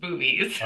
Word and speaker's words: Bubes: Spelled B-U-B-E-S, but it is pronounced boobies.